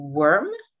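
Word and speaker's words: Wyrm: The Evil Fire Breathing Worm, Not Worm. – No, worm. Eh, det är alltså, uttalas worm som, som mask, alltså, fast det stavas vyrm. Så worm.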